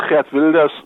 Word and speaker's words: Geert Wilders: Das war auch, äh, oder äh, von dem sagt Gerd Wilders, so spricht man ihn auf Niederländisch, aber wir bleiben bei Gerd, glaube ich.